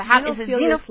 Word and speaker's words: Xenophilius: How do you say his name? – Xenophilius Lovegood. – Xenophilius? – Like, yeah, you know, because, like, bibliophilia is to love books, and xenophobia is to be afraid of open spaces.